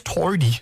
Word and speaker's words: Toady: I'm going to miss Toady for the O in Toady, because that's a uniquely Australian thing, the way they pronounce it. Although there's a bit of Northern Ireland in there as well, is there? – Toady. – Toady? – Toady. – Toady. It's called a diphthong.